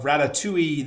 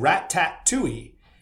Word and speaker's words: ratatouille: I said Rat-tat-tui. And you should have, listen, the one thing you don't want to do on TikTok is outrage Gen Z, because they are brutal and they will come after you, right? So I had so many comments. You pronounced it wrong. How dare you? You pronounced it wrong. It's ratatouille. It's not ratatouille, you old man, like all that stuff, right?